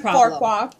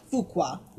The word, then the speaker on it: Fuqua: His name is Antoine Farqua. – Fuqua.